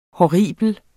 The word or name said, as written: horribel